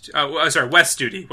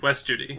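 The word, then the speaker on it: Wes Studi: It's Steve Hooty? – I think that's how you pronounce it. – I hate IMDb's cast listing. It always looks like garbage. – It does. That's true. – West Studi. West Studi. – That's right, yeah.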